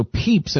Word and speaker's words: Pepys: So Peeps, and it's spelled P-E-P-Y-S, if you want to read the diaries. Jack says they're brilliant. It looks like Pepys, but it's Peeps.